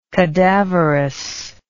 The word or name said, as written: cadaverousness